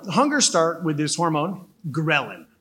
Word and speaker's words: ghrelin: Ghrelin. – Spell it. – Ghrelin. – There's a silent H. – Ghrelin. Yeah, the H is silent.